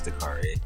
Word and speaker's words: Dakari: Yeah, in the streets, you know, like at work they call me DJ because they can't pronounce it, but it's Dakari. It's Dakari out here.